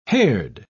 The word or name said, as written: haired